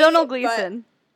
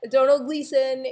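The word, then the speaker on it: Domhnall Gleeson: It's Donal Gleason. – Donal Gleason, he was great too.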